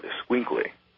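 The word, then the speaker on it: Xoloitzcuintli: And the pronunciation of that is called Esquinkly.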